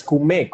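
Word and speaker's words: kumé: Es decir, que yo lo estaba diciendo mal, es CUME con el acento en la E. CUME, ah, mira, aprendimos algo nuevo.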